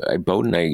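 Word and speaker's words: Boudin: Is it Bowden? How's it pronounced, Kate? Do you know? Bowden. – That's how I've been saying it. – Yeah, I think that's right.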